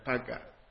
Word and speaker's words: Paga: How do you pronounce it? Pago? – Yeah, Paga.